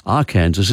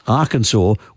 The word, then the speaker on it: Arkansas: The territory, which is now pronounced Arkansas, was settled by French speakers. And so the French interpreted the indigenous Native American word in a French way, and for them, they thought Arkansas was the correct pronunciation.